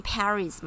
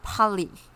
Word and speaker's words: Paris: 所以，如果是如果是巴黎，英文不是念 Paris 吗？P A R I S 吧，是不是？但是呢，为什么中文翻作巴黎？因为法文念 Paris，就这样，它那个 S 是不发音的。